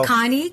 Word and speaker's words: Connie: Connie, QXO, Connie, spelled with an I-E, not a Y.